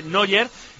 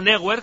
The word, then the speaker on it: Neuer: Por ejemplo, se dice Neuer y no Neuer, que luego nos reímos cuando los alemanes dicen Paella.